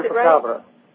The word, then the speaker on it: chupacabra: The reason why I was interested in talking with you was your allusion to your research on chupacabra. Did I pronounce chupacabra.